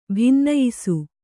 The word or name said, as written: ಬಿನ್ನಯಿಸು